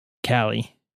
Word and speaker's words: Kailh: I think it's pronounced Kale or Kali. No idea. Box white keys. – I think Kali is what I've heard people say. – Kali. – Yeah. – Like Calico Cat. – Because it's K-A-H-L-I, right? – K-A-I-L-H. – K-A-I-L-H.